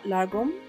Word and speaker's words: Lagom: Y también está Largom, que es L-A-G-O-M, Lagom se escribe, pero es Largom, como investigué yo que se pronunciaba.